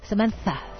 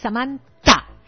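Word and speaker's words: Samanta: ¿Han visto cómo pronuncian la T y la H los americanos? – No. – Ah. Samantha. Así como con Z, ¿no? Bueno, mi nombre es sin H. Es Samantha.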